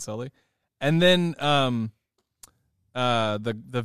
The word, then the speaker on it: Sully: Yeah, so the girl who played Chloe kept calling Sully Sally.